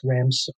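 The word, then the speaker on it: Ramsukh: Roger Ramsuk. That's R-O-G-E-R. And the last name is R-A-M as in Mary, S-U-K-H. And I know it looks weird, but it's pronounced Ramsuk.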